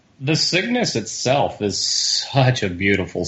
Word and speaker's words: Cygnus: And when the Palomino docked with the Cirrus, I say that right? – Cygnus. – Cygnus, right. Sorry.